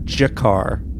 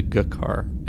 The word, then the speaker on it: Jakar: That I never even said the name Andreas Katsoulis, and then somebody else really came down hard on me for not pronouncing the character's name, Ambassador Jakar, as opposed to Gukar in Babylon 5.